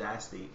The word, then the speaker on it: aesthete: And I'm sorry, for using this word that's pronounced aesthete. But there is a word, and it's aesthete, and as I read this... – From, like, the word from aesthetic. The, yes. – But so, we did look it up. The pronunciation is aesthete.